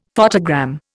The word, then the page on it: photogram: Indian